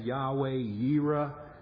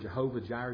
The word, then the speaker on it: Yahweh Yireh: You may have heard Yahweh most often pronounced as Jehovah, just a different way of pronouncing the same Hebrew word. Jehovah, Yahweh, Jireh. That's how we, Jehovah Jireh, Yahweh Yireh is how it's said in Hebrew.